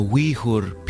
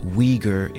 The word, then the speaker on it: Uyghur: And coming up next, music from Xinjiang, China, from the Uyghur people, also often pronounced Uyghur in English, whose Muslim culture is being threatened by the government of China.